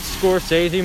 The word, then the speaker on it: Scorsese: Scorsese. – Fuck you. – It's pronounced Scorsese.